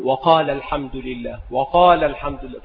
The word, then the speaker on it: وقال الحمد لله: فبعض الناس ينطق بالألف مع الوصل فيقول وقال الحمد لله، هذا خطأ، وإنما تقول وقال الحمد لله، تحذف الألف كما مر في القاعدة، فتقول وقال الحمد لله.